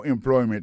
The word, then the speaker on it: employment: mot employment là c'est employment accent tombé sous deuxième syllabe là employment agency pour mot agency hein,